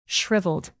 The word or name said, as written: shriveled